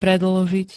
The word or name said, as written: predĺžiť